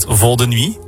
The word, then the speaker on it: Vol de Nuit: And also, 20 years ago, you made Vol de Nuit together. – Yeah, I hope you pronounced it in the right way. – Perfect.